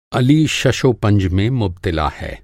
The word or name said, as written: शशोपंज